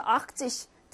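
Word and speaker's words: achtzig: 근데 발음상 트가 잘안 들려서 악지시. 트가 안 들려서 트가 날라갔다고 생각하는데 트 그대로 살아 있습니다.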